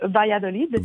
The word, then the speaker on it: Valladolid: Je vous amène d'ailleurs vers l'ouest, vers Valladolid, prononcé en espagnol Valladolid.